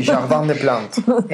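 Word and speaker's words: Jardin des Plantes: Sí, no se dice así. – Es Jardín de las Plantas o de Plantes. – Sí, Jardín de Plantes.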